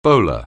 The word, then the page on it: bowler: anglais